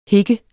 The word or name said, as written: hik